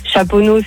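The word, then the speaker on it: Chaponost: Chaponneau, c'est ST, tu le prononces pas.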